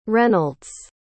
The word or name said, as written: reynolds